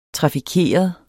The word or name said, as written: trafikeret